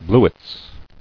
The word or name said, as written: Bluets